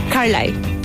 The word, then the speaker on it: Carlyle: Carlyle.